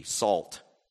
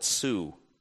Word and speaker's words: Sault: Like the first is S-A-U-L-T, salt, and they call it Sioux.